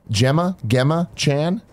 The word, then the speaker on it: Gemma Chan: Gemma Chan, not sure on pronunciation.